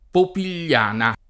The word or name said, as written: Popigliana